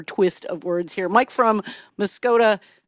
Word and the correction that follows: Muskoday: That's pronounced Muskoday. – Muskoday. I get this wrong every time, Mike. You keep correcting me, and one of these days I'll get Muskoday right.